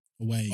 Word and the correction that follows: Huawei: It's pronounced Huawei. Google it. Huawei.